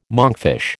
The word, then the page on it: monkfish: American English